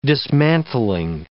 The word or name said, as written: dismantling